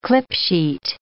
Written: clipsheet

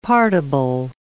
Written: partible

